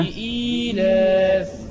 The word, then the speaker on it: لإيلاف: لا لا، أنت قلت لي في أصل همزة وفي ياء، أنا عايز النطق الصحيح. – ليلاس. – لا ليلاس. – ها؟ – لإيلاف. – لإيلاف. – اتفضل. – لإيلاف. – اسمعها بقى. لإيلاف لإيلاف قريش هكذا.